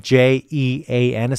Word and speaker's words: Jean: Uh, my name, by the way, it's spelled J E A N. It's a French name.